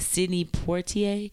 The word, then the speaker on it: Sidney Poitier: I say his name wrong all the time, but Sidney Poitier. – That's right. – Oh, I said it right.